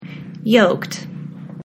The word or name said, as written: yoked